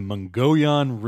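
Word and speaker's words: Mogollon: Our story tonight begins in the Mongolian Rim. – Ooh. – And that's how you pronounce that, according to the National Park Service.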